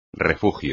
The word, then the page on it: refugio: espagnol